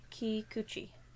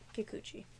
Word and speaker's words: Kikuchi: Rinko Kikuchi. Kikuchi.